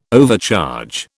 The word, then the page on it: overcharge: British English